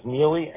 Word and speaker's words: Miele: Last name is Neely, M like in Mary, I-E-L. M-I-E-L-E. – Got it. M-I-E-L-E.